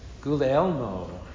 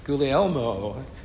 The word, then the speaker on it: Guglielmo: In Italy, I kind of like this, Guglielmo. Does that sound kind of cool? Guglielmo.